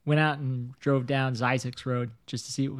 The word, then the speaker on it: Zzyzx: Zizix is how it's pronounced.